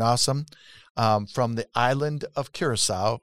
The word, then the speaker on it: Curaçao: I want to introduce everybody to Chandra Smaus, who is International Liaison for North America for the island of Curaçao.